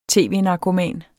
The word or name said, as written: tv-narkoman